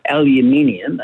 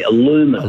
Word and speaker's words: aluminium: One is, we say aluminium. Americans say aluminum. – Aluminum, yeah.